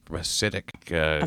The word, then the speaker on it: Hasidic: Because I noticed your slight correction of Hasidic. Hasidic.